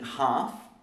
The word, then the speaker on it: half: We spell half with an L. It looks like this. Half, and we don't really say the L.